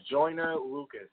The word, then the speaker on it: Joyner Lucas: There's a video out on the internet by Joyner Lucas. Am I saying that correctly? – Yeah. – Joyner Lucas.